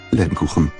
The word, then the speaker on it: Lebkuchen: Lebkuchen.